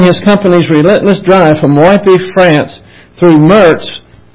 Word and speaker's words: Woippy: The word is spelled W-O-I-P-P-Y, so I say Woypy, France is what I'm going to call it. It's closest I can get.